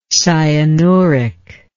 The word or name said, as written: cyanuric